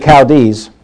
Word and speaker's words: Chaldees: When he left Ur of the Chaldees, did I say that right? – Yeah.